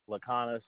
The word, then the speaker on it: Laconis: Laconis, LLACONIS.